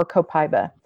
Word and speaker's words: copaiba: My top one, if you ask me anything, is going to either be frankincense or copaiba. And it's spelled C-O-P-A-I-B-A. It's actually pronounced, I guess, in most countries where it's grown, copaiba, but copaiba is easier.